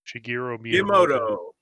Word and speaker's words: Shigeru Miyamoto: So I watched a couple of videos of I cannot pronounce his name, the creator of Mario, Shigeru Miyamoto.